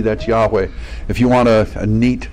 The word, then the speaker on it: Yahweh: and it's Yahweh would be the best pronunciation of it.